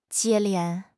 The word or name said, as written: 接连